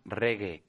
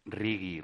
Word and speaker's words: reggae: Estás escuchando Cinta de Varios y ahí teníamos a Nina Hagen con la canción African Reggae. Chris, que te lo he dicho mil veces, que se dice reggae, no se dice riggy.